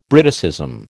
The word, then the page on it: Briticism: American English